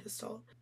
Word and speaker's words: Gestalt: It's spelled Gestalt. – Okay. – It looks like just salt, but it's Gestalt.